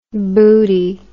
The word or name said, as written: boody